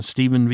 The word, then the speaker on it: Stephen: I'm Stephen V.